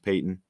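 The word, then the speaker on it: Paton: Looks like Patton, but I believe it's pronounced Payton.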